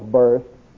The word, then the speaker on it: burst: I stumble and I stump my toe and I fall flat on my face and bust my nose wide open. Now, I know I pronounced it wrong. I know it's burst.